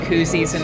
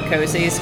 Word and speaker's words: koozies: I really like the fact that she actually called them koozies and not cozies, because that's the correct pronunciation.